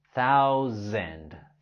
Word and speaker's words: thousand: En la palabra thousand, la letra S lo pronunciamos como Z, Z, como Z. Thousand, thousand.